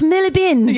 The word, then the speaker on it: Marylebone: So this John Bingham, during World War II, when he was a boy, he was evacuated out of London, out of Marylebone. They're gonna be like, it's pronounced Millibin.